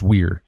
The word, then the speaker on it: vir: You know, our word virtue comes from the Latin word virtus, V-I-R-T-U-S, which means manliness. The word for man in Latin was a vir, V-I-R. It's pronounced weir.